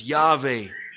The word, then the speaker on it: Yahweh: What's called the Tetragrammaton, the four letters, Yod, He, Vav, He, which we pronounce as Yahweh.